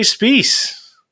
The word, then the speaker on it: Spies: And despite how it's spelt, his name rhymes with peace. It's Jeremy Spies.